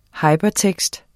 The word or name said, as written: Hypertekst